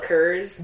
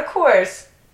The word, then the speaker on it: Coors: How do you say the beer C-O-O-R-S? – C-O-O-R-S? – Mm-hmm. – I mean, isn't it Coors, but people call it Kurz? – Yep. Yes. Ew. He legitimately ordered a Kurz Lot. Not a Coors.